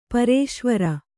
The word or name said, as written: ಪರೇಶ್ವರ